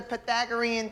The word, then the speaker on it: Pythagorean: Now, from your first night of homework, you all should be very familiar with the Pythagorean theorem. All right? Say it with me. Pythagorean. – Pythagorean. – Pythagorean. – Theorem. – Theorem. – Theorem. Theorem. – That's how you say it.